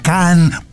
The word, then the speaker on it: Cannes: En francés se pronuncia can, pelao, porque esas S atrás no suenan.